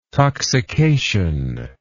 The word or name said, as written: toxication